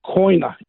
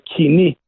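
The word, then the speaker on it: koine: We call it koina in English. They butchered the Greek language. It's supposed to say kini, which means the common language.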